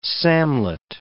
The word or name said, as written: samlet